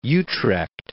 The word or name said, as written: Utrecht